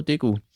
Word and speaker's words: Degu: Ratte oder, ja, wie spricht man das aus? – Degu, oder? – Degu? – Ich muss ja. Einfach nur Degu.